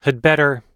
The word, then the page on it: had better: American English